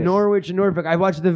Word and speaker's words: Norwich: It's spelt Norwich, but it's pronounced Norwich.